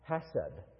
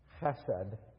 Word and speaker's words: chesed: In the Hebrew language, there is this marvelous word, hesed. Now, you look at it on a page, transliterated into English, it looks like chesed, C-H-E-S-E-D. That's pronounced chesed.